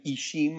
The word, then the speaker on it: ixim: It also kind of Ishim, IXIM, the X is pronounced as sh, means corn. So it could be a game of Ishim for corn and Maya, and then we just use two M's.